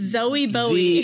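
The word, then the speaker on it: Zowie Bowie: Well, it's spelled exactly like Zoe, or I mean, it's spelled exactly like Bowie, but with Zowie. A Z. It's Zoe Bowie. – Z-O-W-I-E. Yeah.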